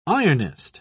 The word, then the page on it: ironist: British English